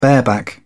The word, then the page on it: bareback: British English